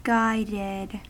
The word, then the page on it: guided: American English